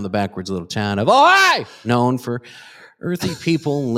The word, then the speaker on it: Ojai: There is unrest in the scenic little town of, and I don't know how to pronounce this, Scooter. Is it OJ, California? – Ojai.